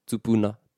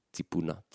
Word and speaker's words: tupuna: So tupu, tupuna, tupuna. And then towards the east coast of Te Kau Māui, you hear the E being pronounced more. Tipu, tipuna, tipuna.